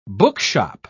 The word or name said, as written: bookshop